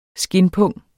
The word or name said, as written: skindpung